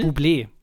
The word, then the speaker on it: Bublé: Michael Bubble. Vielleicht? Bubble? Bublé. Bublé? – Bublé. Ja, der ist Kanadier, deswegen wird das so komisch ja, ausgesprochen.